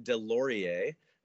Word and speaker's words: Delaurier: But it's by an artist named Gary Delaurier. It's the sort of French pronunciation.